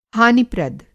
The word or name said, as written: हानिप्रद